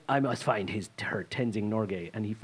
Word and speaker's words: Tenzing Norgay: No, is that the wrong person I'm thinking of? – Yeah, that's how you pronounce it. – That's his name, Tenzing Norgay.